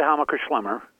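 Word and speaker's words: Hammacher Schlemmer: Well, you say it Hameker Schlemmer.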